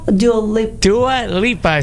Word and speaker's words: Dua Lipa: Dua Lipa is how you pronounce her name.